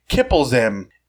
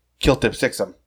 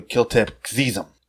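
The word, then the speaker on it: Kltpzyxm: And also, as far as mispronunciation goes, Kiplezim is not how you say his name backward. That is Kiltip Sixum, or Kiltip Zizum. In Lois and Clark, Howie Mandel pronounced it Kiltip Sixum. I believe the proper pronunciation is actually Kiltip Xizum.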